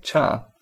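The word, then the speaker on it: cha (茶): The Chinese character for tea is pronounced in Mandarin Chinese as cha, and is the source of words for tea in many Eastern languages.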